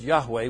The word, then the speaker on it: Yahweh: In the late 19th century, they inserted 3 vowels rather than 2 and ended up with Jehovah. But we think the word was probably pronounced Yahweh.